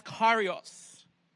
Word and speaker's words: Kyrios: You know, the Greek word for Lord, pronounced Kyrios, its meaning is master, owner, one in authority.